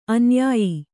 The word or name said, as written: ಅನುಯಾಯಿ